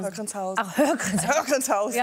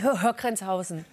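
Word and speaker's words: Höhr-Grenzhausen: Hörgrenzhausen. – Ja. Wo, wie aufregend war das Teenagerleben in Hörgrenzhausen? – Also, es war aufregend, ja.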